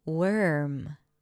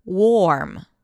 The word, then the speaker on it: worm: Now again, if you were to pronounce the O as an O, O, warm, warm. And that's a different word, W-A-R-M, that you might use to describe the weather. It's pretty warm. But worm, W-O-R-M, is the small, soft creature that has a tube-like shape.